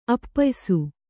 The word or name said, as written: ಅಪ್ಪಯಿಸು